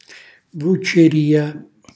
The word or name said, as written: vucceria